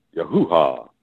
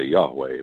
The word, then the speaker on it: Yahuwah: Yahuwah, his name. Some people say Yahweh, but it has to have three syllables. Can't just have two the way it's written.